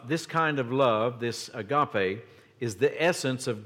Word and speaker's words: agape: The Greek word here that the New Testament was translated from is the word agape, kind of an unusual word for an English speaker, A-G-A-P-E. It is pronounced agape.